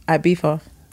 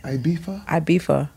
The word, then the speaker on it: Ibiza: Ibiza. – That's how you say it? – Yeah. – Ibiza.